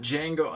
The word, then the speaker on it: Django: He's like, D-J-A-N-G-O. And the D is silent.